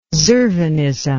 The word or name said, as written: Zurvanism